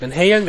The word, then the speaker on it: Van Halen: Nein, du hast es schon richtig gesagt. Van Halen,